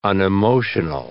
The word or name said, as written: unemotional